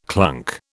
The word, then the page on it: clunk: British English